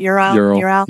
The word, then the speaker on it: Ural: It was a Ural. That's how you say it. Ural.